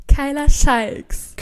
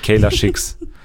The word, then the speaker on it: Kyla Shikes: Wie spricht man nochmal die Insektenjägerin aus? – Kyla Shikes. – Kyla Shikes, okay. – Ja. – Und nicht Kayla Schicks.